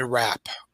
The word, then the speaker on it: Rappe: And he invited a whole bunch of people to come and party, including a 26-year-old actress named Virginia Rapp. Last name is spelled R-A-P-P-E.